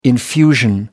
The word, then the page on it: infusion: American English